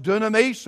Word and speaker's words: dunamis: And the word there is pronounced dunamis, and we've all heard the word, most of us have.